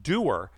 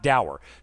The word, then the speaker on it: dour: At the end of the day, though, my all too often cynical and doer, because that's how you pronounce it, guys, it's not dour, cynical and doer worldview get the best of me.